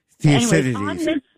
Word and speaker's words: Thucydides: by an Athenian historian and general, I hope I pronounce his name right, Thucydides. – Thucydides. – Yes, exactly.